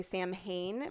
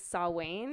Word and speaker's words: Samhain: a lot of people say Samhain, but it's Samhain.